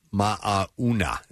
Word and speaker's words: Mauna: Mauna is how you say it. – Mauna. Look at you like a local. – where crews play. It's funny about all, you have to pronounce all of the vowels. And they have different pronunciations than most of the vowel sounds.